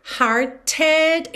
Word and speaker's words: hearted: De novo aqui, hearted, esse E final é pronunciado e o T foi modificado para rarara, por isso está pintadinho de azul claro.